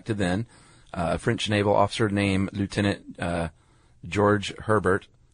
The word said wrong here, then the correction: Georges Hébert: I don't think that's how you say his name. – What would you say in French? – Georges Hébert. – Georges Hébert.